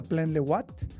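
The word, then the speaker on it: Plan-les-Ouates: Eh, les, les Ouates, se pronuncia Plan Le Watt.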